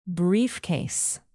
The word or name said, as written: briefcase